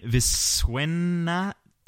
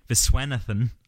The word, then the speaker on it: Viswanathan: Viswanathan. – Okay, very good. – Viswanathan, I think, is how I've looked up a classic pronunciation guide there, Michael.